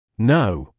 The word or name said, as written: know